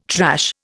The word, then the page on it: trash: Indian